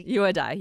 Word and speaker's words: Yahudai: Did I say it right? Yahudai? I'm sorry. – No, it's very close. Yeah, all right. Yahudai.